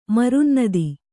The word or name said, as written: ಮರುನ್ನದಿ